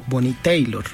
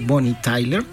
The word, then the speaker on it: Bonnie Tyler: Bonnie Tyler la conocen todos los, conocedores de la música. Sin embargo, como ella es galesa, entonces mucha pronunciación era Bonnie Tyler. Entonces mucha gente le dijo Bonnie Taylor. Ajá. Pero en realidad Bonnie Tyler es una de las voces más representativas.